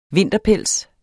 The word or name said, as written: vinterpels